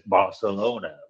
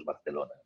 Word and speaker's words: Barcelona: Como it is in, no digáis Barcelona. Bueno, pues ¿por qué Barcelona? Pues si es Barcelona, pues Barcelona, ¿no?